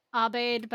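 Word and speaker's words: Aubade: Abed by Philip Larkin. That's spelled A-U-B-A-D as in dog, E.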